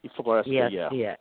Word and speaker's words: Floresca: Floresca, I believe it was. – Pronounced. Yeah. – Floresca, F-L-O-R. – Floresca, yeah.